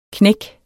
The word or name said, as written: knæk